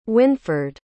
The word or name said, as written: Winford